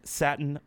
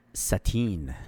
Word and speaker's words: Satin: Getting out there and getting into all these raffles for the Air Jordan 1 Satin Black Toe. – Yeah, I think it's what a sick shoe. – Sateen. – What's that? – It's pronounced sateen.